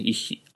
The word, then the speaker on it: ich: 一比。 I、 ICH で一比。 CH で非って読むんですね。ドイツ語はね。